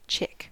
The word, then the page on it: chick: American English